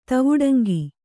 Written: ತವುಡಂಗಿ

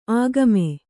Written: ಆಗಾಮಿ

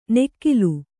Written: ನೆಕ್ಕಿಲು

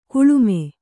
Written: ಕೆಳಮೈ

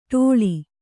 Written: ಡೊಳ್ಳ

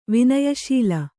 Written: ವಿನಯಶೀಲ

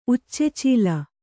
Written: ಉಚ್ಚೆಚೀಲ

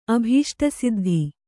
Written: ಅಭೀಷ್ಟಸಿದ್ಧಿ

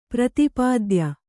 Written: ಪ್ರತಿಪಾದ್ಯ

